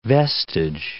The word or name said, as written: vestige